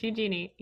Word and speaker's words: Eugenie: It's Eugenie.